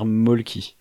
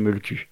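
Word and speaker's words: Mölkky: La vraie prononciation, c'est meule-cul. Mais j'ai toujours dit Molki et je suis trop habitué à dire mal le truc, donc je le prononcerai mal.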